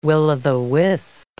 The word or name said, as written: will-o'-the-wisp